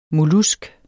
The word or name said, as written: mollusk